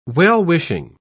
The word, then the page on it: well-wishing: British English